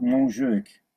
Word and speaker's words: Montjuïc: How do you pronounce it? – We're called Montjuïc.